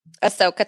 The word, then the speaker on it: Ahsoka: Is it Ahsoka? Is that how you say it? – Ahsoka Tano, yeah.